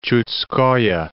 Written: Chudskoe

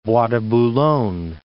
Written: Bois de Boulogne

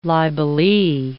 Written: libelee

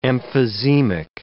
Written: emphysemic

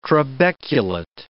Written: trabeculate

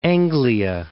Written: East Anglia

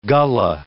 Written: Gullah

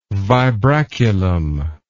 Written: vibraculum

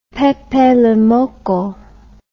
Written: Pepe le Moko

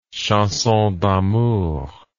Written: chanson d'amour

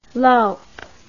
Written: lowp

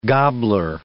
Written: gobbler

